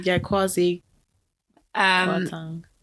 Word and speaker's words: Kwasi Kwarteng: And she was recorded secretly saying some nasty things about our Chancellor, Kwasi Kwarteng. Is that how you say his name? Kwarteng. – Kwasi Kwarteng. Yeah, I can't see his name written anywhere right now for me too.